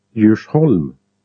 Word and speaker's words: Djursholm: The initial letter is not pronounced in these groups, so all of them are pronounced as if they were Swedish J. Repeat after the speaker. DJ. Place names. – Djursholm.